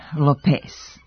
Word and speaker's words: Lopez: U drugom slučaju, u jednoj od vaših reklama pominje se ime Jennifer Lopez, sa akcentom njenog prezimena na drugom slogu. E pa ovdje je akcenat na prvom slogu, a ne na drugom.